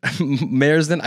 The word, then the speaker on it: Märzen: I believe I said that right. Märzen. I looked up a pronunciation right before this.